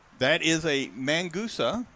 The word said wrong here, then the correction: Mangusta: Does that say it right? – Mangusta. Yeah.